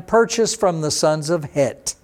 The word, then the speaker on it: Het: And remember, in Hebrew, when you see a TH, you don't pronounce that as Heth, as you would see in your English Bible, H-E-T-H, because in Hebrew you don't have that TH sound. That's just there to accommodate English speakers.